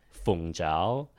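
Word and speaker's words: Fung jiao (鳳爪): Fung jiao.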